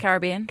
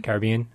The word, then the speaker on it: Caribbean: Or Caribbean. How do you guys pronounce it? – Caribbean. – Caribbean, yeah. And I said, no, I want to go to Europe.